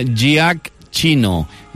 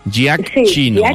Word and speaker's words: Giacchino: Per si ho voleu buscar, s'escriu Michael en anglès, Michael, i Giacchino és amb G, Giacchino. Giacchino, G-I-A-C-C-H-I-N-O.